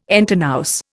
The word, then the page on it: entre nous: Indian